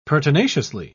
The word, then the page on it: pertinaciously: British English